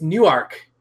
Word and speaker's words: Newark: All the way from New York. – Jersey. – Close enough. – New York? How do you pronounce that? Because it's not New York. It's Newark. – Newark, New Jersey.